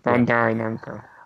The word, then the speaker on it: Bandai Namco: Bandai Namco.